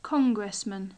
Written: congressman

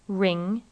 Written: ring

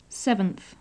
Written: seventh

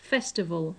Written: festival

